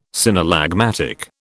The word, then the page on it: synallagmatic: British English